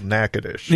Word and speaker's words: Natchitoches: Now, for our listeners out there, if you go to look that up on a map, in Yankee, it's spelled Natchez Noches, but in real speak down there, it's called Natchitoches.